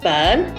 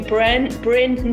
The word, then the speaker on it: Byrne: People often call me Bryn, not Byrne. So I get that. Or people will spell it Bryn, B what, instead of Byrne.